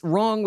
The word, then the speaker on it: wrong: Now, it's wrong with a silent W at the beginning.